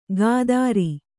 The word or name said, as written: ಖಾತ್ರಿ